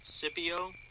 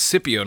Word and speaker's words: Scipio: Scipio. – Oh, it's Scipio, not Scipio.